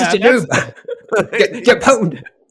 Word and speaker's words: pwned: We pwned them? – Yeah. Yes, we pwned. Yeah. I actually had to ask how it's pronounced, because I like the word pond, because it sounds like, you know, like in chess, you just, you know, they're a noob, have no power.